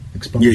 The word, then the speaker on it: expunction: Is that how you say it? Expunction? – Expunction. Yeah, yes, okay.